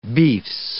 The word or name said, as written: beefs